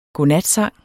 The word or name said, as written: godnatsang